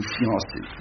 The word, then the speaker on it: fiancé: Deux, il n'y a pas de i, c'est pas, il y a un iode à la place de i. Fian. Donc fian, une syllabe, c'est deux syllabes.